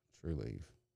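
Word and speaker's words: Trulieve: True Leave. It's spelled like Believe.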